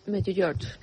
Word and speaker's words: Medjugorje: Medjugorje.